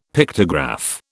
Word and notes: Pictograph: British English

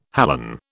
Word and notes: halon: British English

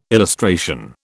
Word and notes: illustration: British English

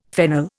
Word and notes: fennel: Indian

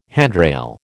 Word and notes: handrail: American English

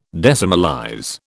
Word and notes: decimalize: British English

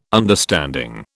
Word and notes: understanding: British English

